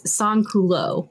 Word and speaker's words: sans-culottes: So the answer is sans culot, which is what the lower class were called because they didn't get to wear the fancy pants the aristocracy had. So, and that is spelled sans-culottes, since you need the last letter for this mechanic.